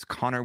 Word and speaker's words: Conor: So, and if anybody's listening on audio, it's Connor with one N, Daily 22 on all the freaking socials.